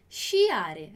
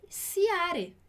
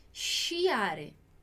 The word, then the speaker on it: sciare: É não dizer sciare. A gente teria essa tendência aqui, né? Sciare, não, vai ser sciare, sciare. Então,